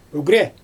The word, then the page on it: ygrɛ: Xyzyl